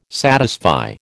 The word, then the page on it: satisfy: American English